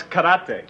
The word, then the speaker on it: karate: And how do you say that now? – No, it's karate. – Oh. – It's a Japanese word. It means empty hand.